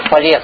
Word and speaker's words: Фалес: И самый первый, с кого начинается философия, чего-то именем назовем, это Полес. Ударение на последний слог.